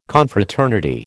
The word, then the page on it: Confraternity: American English